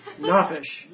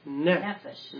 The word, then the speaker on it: Nefesh: Nafish. – No, the emphasis on the N-E. – Nefesh.